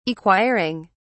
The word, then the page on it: acquiring: British English